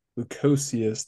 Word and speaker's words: Lukosius: But Lukosius, there we go. I was wanting to start it like Luka Doncic, mm-hmm.